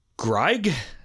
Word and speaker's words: Greig: Uh, tenor Mark Dobell, tenor Angus Smith, and baritone Donald Greig. It's spelled G R I E I G, so it's not. It's like the opposite of Grieg in German. It would be Greig.